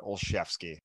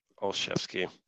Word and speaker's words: Olszewski: You said it wrong though. He stepped right into it. yeah. Ugh. – You put an extra L in there. – Gunnar Olszewski. Olszewski. Olszewski. Yeah.